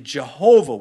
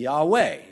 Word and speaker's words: Yahweh: Scholars used to believe Jehovah was the proper pronunciation, but modern scholarship with more research, collective research, have come to the conclusion it's probably going to be Yahweh. You won't be wrong either way, but I don't want to give the Jehovah's Witness satisfaction because they can't even get the pronunciation right.